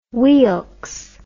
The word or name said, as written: Weelkes